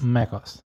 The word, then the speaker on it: macOS: I think it's pronounced macOS. – macOS.